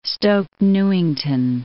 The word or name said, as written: Stoke Newington